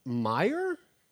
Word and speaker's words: Meier: We are sitting here with Dr. Sandra Meier.